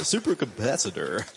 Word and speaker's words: supercapacitor: They have a supercapacitor. – They have a supercalifragilisticexpialidocious. – Did I say it incorrectly? I think I did. I said supercapacitator.